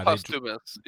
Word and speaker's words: posthumous: Yeah, I don't know how I still feel about post humorous post. Is that how you say it? Yeah. I mean, after people die, posthumous.